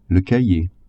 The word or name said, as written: cahier